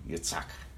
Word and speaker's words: Yitzhak: In the Hebrew pronunciation, Yitzhak. But Abraham's the first one to laugh at this.